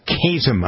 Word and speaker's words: KASM: So, all right, we're having a conversation with Joe Gill, the farm director at Kasem. I like that. You call it Kasem, right? K-A-S-M. Kasem. – Chasm. Kind of rhymes with spasm, yes.